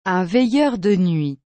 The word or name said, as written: un veilleur de nuit